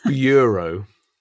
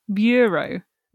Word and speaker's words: bureau: Nope, stop putting an R in it there where there isn't one. Bureau. – Bureau.